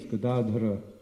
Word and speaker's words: Gadadhara: It's Gadadhara, the accent's on the second syllable, not on the first or the third. Gada means a club, and Dhara means one who holds. So Krishna's name is Gadadhara, one who holds the Gada.